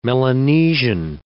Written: Melanesian

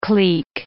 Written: cleek

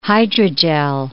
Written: hydrogel

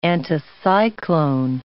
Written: anticyclone

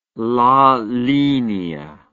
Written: la linea

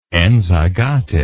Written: enzygotic

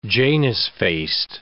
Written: Janus-faced

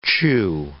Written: chew

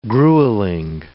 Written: gruelling

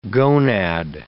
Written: gonad

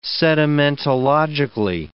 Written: sedimentologically

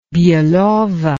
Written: belovo

